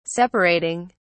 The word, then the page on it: separating: British English